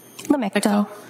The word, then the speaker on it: Lamictal: So the Lamictal, am I saying that correctly? – Lamictal.